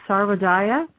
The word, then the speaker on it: Sarvodaya: Sarvodaya? Am I saying that right? – Yes, you are.